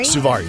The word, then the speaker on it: Suvari: Suvari, yeah.